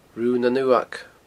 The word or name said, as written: Rubha nan Uamhag